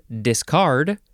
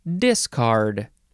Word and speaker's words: discard: Next is discard or discard. Emphasis on either syllable is quite all right with me.